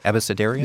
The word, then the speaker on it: abecedarian: Your very first entry is the word, I don't know if I'm saying this right, abecedarian? Abecedarian? – Yes, that's it.